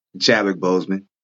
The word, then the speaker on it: Chadwick Boseman: Chadwick Boseman.